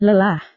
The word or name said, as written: lelah